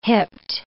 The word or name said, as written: hipped